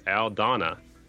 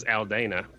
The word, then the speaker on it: Aldana: People always say his name incorrectly, and I was fortunate enough to talk to him a long time ago, and it is Aldana. – Yeah. – And everybody says Aldana, but that's how it looks like, you know.